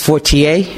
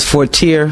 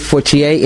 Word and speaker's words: Fortier: My maiden name is pronounced Fortier. People pronounce it Fortier, Frontier, but it is actually Fortier, and it is a Cajun French name.